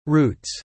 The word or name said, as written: roots